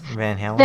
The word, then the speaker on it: Van Halen: Van Halen.